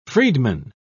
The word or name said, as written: freedman